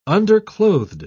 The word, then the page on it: underclothed: British English